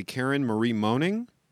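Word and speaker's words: Karen Marie Moning: So that's Dark Fever by, and I'm going to make sure I'm pronouncing it correctly, Karen Marie Moaning? – Yes.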